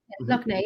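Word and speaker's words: Lough Neagh: Is it Loch Ney? Am I thinking saying it right? – Yeah. – Loch Ney, yeah.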